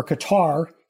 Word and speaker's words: Qatar: and the director of law enforcement programs at the Qatar, or Qatar, as most Americans pronounce it, International Academy for Security Studies.